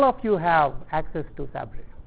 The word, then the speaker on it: Sabre: It's called Sabre. I think it's S-A-B-R-E. I'm just pronouncing it Sabre.